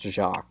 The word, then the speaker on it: Jacques: Have I been saying it wrong this entire time? – I assume you have. Like, yeah, it is pronounced Jacques.